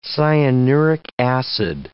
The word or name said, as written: cyanuric acid